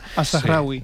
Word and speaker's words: Al-Zahrawi: Pues mira, el primero que he traído es Al-Zahrawi, ya me dirá si lo he pronunciado bien o mal. – Al-Zahrawi. – Sí. Perfecto, pues lo he pronunciado bien. – Mal. Es una buena manera de no decírmelo abiertamente. – Sí, no, bien, bien.